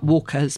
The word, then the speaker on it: warqa: I thought it was just Walker, W-A-L-K-E-R, as in White Walkers. But it's not. It's spelt W-A-R-Q-A, mm-hmm. Walker. And we say brick pastry, but it's very often spelt B-R-I-K as well.